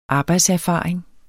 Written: arbejdserfaring

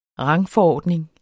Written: rangforordning

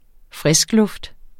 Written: friskluft

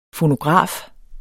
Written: fonograf

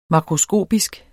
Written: makroskopisk

